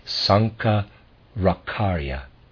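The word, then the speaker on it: Sankaracharya: Sankara-karya, I'm going to spell his name, S-A-N-K-A-R-A-C-H-A-R-Y-A. I'm going to pronounce that Sankara-karya.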